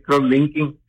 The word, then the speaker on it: cross-linking: Dice acá que usted va a explicar algo que se llama cross-linking. Así se pronuncia, ¿está bien? – Sí, fíjese que así se pronuncia, cross-linking.